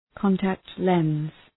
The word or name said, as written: contact lens